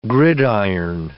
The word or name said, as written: gridiron